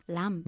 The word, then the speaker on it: lamb: ¿Con la B que se pronuncia o una B muda? – Muda. – Ah, entonces... – Lamb.